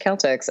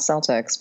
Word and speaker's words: Celtics: And also, I'm so happy to now know that it's pronounced Celtics and not Celtics because no one talks about these things. Yeah, yeah. So I, it's it's supposed to be like Celtic.